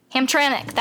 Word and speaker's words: Hamtramck: Hamtramck, that's how you say it.